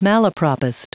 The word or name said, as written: malapropist